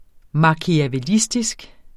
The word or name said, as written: machiavellistisk